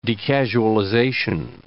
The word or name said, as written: decasualization